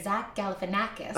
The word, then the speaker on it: Galifianakis: It was about one of my favorite actors, Zach Galifianakis.